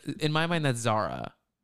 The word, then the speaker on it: Zara: she introduces herself as Sarah, but it's spelled Z-A-R-A. And I'm like, it's Zara.